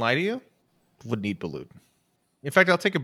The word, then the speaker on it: balut: Do you pronounce the T? – Hey, I'm not a medical professional. Mm-hmm. It looks like I'm looking at the phonetic pronunciation, and it looks like balut or balut. The second pronunciation has the T on it.